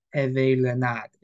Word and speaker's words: Hervé Renard: Um, I was before the podcast, I was frantically trying to work out and look up how to pronounce his name, and I've got to be very French with it, but I believe it's like Hervé Renard is like the the French way of saying it, and I wanna I wanna honor him.